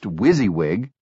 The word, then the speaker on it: WYSIWYG: WYSIWYG, pronounced wizzywig, an acronym for What You See is What You Get.